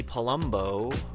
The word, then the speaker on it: Palumbo: Miss New Jersey, Amy Palumbo, rhymes with Columbo.